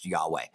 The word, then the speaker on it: Yahweh: And we pretty much know that it's not Jehovah, and that it's YHWH, most likely pronounced Yahweh.